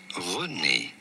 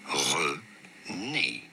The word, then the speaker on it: René: how do we say it in France? Oh. We say René, René, René.